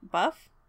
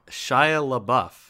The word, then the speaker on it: Shia LaBeouf: Buff? How do you say his name? – No. – Shia LaBeouf.